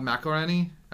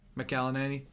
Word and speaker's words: McElhenney: He also owns a football club out in England now with Rob McElhenney. I still don't know how to say McElhenney. – His name. McElhenney.